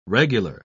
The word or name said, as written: regular